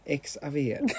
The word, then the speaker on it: Xavier: Xavier, precis.